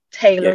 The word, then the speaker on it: Taylor: It's spelled J-U-A-M-I Taylor, T-A-Y-L-O-R.